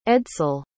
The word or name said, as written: edsel